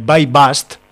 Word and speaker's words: BuyBust: Eh, este fin de semana me he trapiñado una película filipina de 2018 que se hace llamar eh, By Bust se escribe Buibust, eh, boost B-U-Y-B-U-S-T, para quien quiera buscarla por ahí.